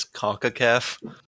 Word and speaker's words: CONCACAF: So Brendy, what's been going on in CONCACAF? Let's start with your boys. – Wait, hold on. Do people actually refer to it as CONCACAF? – Yes, yeah. that's how it's pronounced.